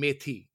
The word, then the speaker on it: methi: And then methi is the way we pronounce it in India and Pakistan.